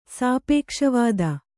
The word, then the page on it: ಸಾಪೇಕ್ಷವಾದ: kannada